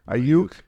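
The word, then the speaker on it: Aiyuk: Ayuk. – Ayuk.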